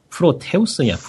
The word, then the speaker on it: Proteus: 그 다음 게임은 이 게임을 볼 때마다 발음이 어떻게 되는 건지 헷갈리는데 프로테우스냐 프로테스냐 P-R-O-T-E-U-S 프로테우스 아니야? 프로테우스? 프로테서스? 응. 음. – 아 이거? 프로테우스.